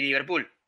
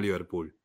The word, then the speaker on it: Liverpool: En, Uruguay, le dicen, en Liverpool, con el acento en la, en doble O. – Como no decimos River, para referirnos a River, no decimos Liverpool.